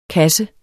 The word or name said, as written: kasse